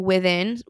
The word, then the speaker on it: WTHN: It's from the brand Within, which is spelled as W-T-H-N.